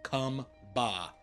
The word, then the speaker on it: Chumba: Well, chumba. – Dark Cloud. It's Dark Cloud says it's actually pronounced cum ba.